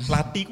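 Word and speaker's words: Lathi: Hmm, lati. Itu. Bacanya lati. Lati. Lati, TH. Lati.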